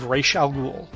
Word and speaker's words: Ra's al Ghul: The Saga of Ra's al Ghul. – Is that how we're pronouncing it? – Yes.